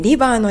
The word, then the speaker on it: liver: えー、リバーのリは、リとレの間のような音で L で発音します。